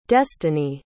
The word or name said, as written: destiney